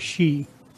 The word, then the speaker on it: Sidhe: With the Siddhi, S-I-D-H-E? – Yeah, with the she. – She. – Pronounced like she. – Oh, uh-huh. Like she, okay.